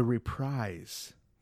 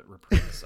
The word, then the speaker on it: reprise: Is it called Reprise? Is that how you say it? – I think it's Reprise. – I've been saying that wrong my whole life. – I've been saying it Reprise the entirety of my life, and then I went back to school for music, and everybody was like, Reprise, and I was like, okay, I'm an idiot.